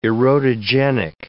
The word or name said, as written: erotogenic